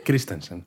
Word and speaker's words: Christensen: También vuelve eh, Hayden Christiansen. – Christensen. – Christensen, siempre lo digo mal.